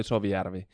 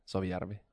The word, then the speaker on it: Soviari: It's Ali Soviari. And your American R is like R, but we pronounce like more like R, Soviari.